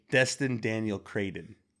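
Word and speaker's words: Destin Daniel Cretton: Daniel Dustin Craig, make that money. – I don't think he got his name right. What? – How do you say his name? – Destin Daniel Crayden.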